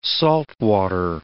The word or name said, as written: saltwater